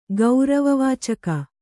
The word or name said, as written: ಗೌರವವಾಚಕ